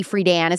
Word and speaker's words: Friedan: What do you think that if Betty Friedan was alive today, or Betty Friedan is how you say her name, if Betty Friedan was alive today, what do you think she would think about the feminist movement allowing men into it now? – I think she was one tough cookie.